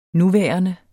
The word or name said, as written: nuværende